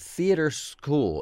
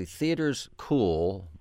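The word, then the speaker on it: Theaterschool: Theater School. It's actually Theaters Cool, but it's spelled all together, and it's supposed to be sort of this pun kind of thing, on North Main Street in Bloomington.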